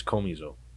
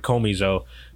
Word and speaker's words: komizo: Well, you said komizo is like more like an accent on the O. Komizo. Yeah. – Komizo. I'm not sure, but it's okay. K-O-M-I-Z-O.